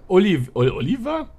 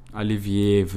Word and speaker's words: Olivier: Der Oliver? Olivier wird es ausgesprochen.